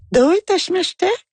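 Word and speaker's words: Doitashimashite (どういたしまして): » Ben j'ai dit « doit », elle me dit « oh non ». J'ai dit quoi? Non, je prononce pas correctement. Ouais. Elle dit non, il faut dire Doitashimashite.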